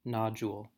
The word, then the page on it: nodule: American English